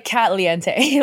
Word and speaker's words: Katliente: It's like Caliente in Spanish, but with K-A-T in the front. And no, my name is not Katie. It's Kat. People always mispronounce it because it looks like Katie if you're not paying attention. – It's like Kate Lee. – Yeah, it's like Katliente, like that, not like Katie.